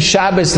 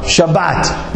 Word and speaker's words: Shabbat: Well, stop saying Shabbos like such an Ashkenazi. Maybe say like a Svardi, a good Svardi. How do you say Shabbos like a good Svardi? Shabbat. What does Shabbat sound like? It sounds like Sabbat.